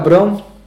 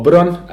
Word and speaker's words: Brown: Uh, Jose Luis Tata Brown, or Bron, as Carlos Bilardo always used to uh, pronounce it, passed away on Monday.